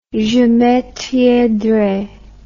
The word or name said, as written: je maintiendrai